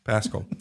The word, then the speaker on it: Paschal: Paschal.